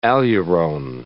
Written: aleurone

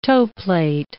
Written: toeplate

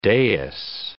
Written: dais

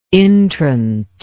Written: intrant

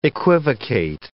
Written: equivocate

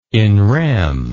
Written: in rem

